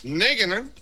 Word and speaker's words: Nagelsmann: Habló también el director técnico de la selección nacional más joven de toda la justa deportiva, Julian Nagelsmann. Ojalá que no haya devastado su... así se pronuncia, pueblo hermano. – Está perfecto, sí.